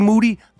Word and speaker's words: Moody: Me, G Moody. Last name rhymes with duty.